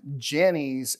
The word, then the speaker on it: Jannes: And then it ends with, Paul's buildup is, just as Jannes and Jambres, and yes, that's the proper way to say it,